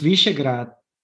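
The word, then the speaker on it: Višegrad: And it's also very interesting because it's told since Visegrad, did I pronounce it correctly? Yeah, okay. great.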